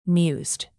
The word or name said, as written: mused